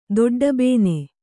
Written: ದೊಡ್ಡಬೇನೆ

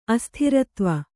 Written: ಅಸ್ಥಿರತ್ವ

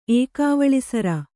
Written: ಏಕಾವಳಿಸರ

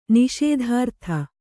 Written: ನಿಷೇಧಾರ್ಥ

